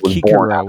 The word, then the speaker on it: Cicero: Yeah, and then, but anyways, I've come to learn it's pronounced Kikero, which is weird. – What was that? – I said I've come to learn it's actually pronounced Kikero. The C's in ancient Latin were pronounced as K's.